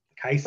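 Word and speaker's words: Kajsa: In Sweden, the J makes an I. – Kaisa.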